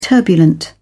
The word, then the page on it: turbulent: British English